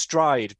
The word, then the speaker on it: Stryd: Which oh yeah, it's, so it's it's pronounced stride, but it's spelled S T R Y D.